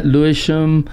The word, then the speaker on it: Lewisham: Yeah, Lewisham.